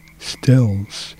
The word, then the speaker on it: Stills: And then we ended with one of my favorite Buffalo Springfield tracks from the Immortal Again album from 67, and Steve Sills. Am I saying his name right? Steve Stills.